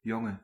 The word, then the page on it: male: /ˈmeɪl/; American English; [ˈmeɪl]; /ˈmeɪəl/